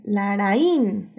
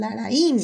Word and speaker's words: Larraín: Laraín. Es que es con acento en ahí aparte, entonces Laraín.